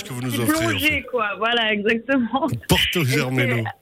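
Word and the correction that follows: Germeno: Ouais, alors à la base, on prononce Yermeno. – Yermeno, ah, pardon. – Voilà, mais je sais que personne ne le dira.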